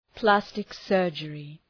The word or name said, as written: plastic surgery